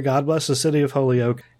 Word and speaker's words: Holyoke: But how did you pronounce this? Holyoke? – Holyoke. – Holyoke, yeah.